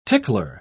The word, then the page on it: tickler: British English